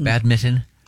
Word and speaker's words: badminton: You don't pronounce that N? – You do not. – No, badminton. – Badminton.